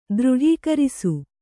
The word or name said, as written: ದೃಢೀಕರಿಸು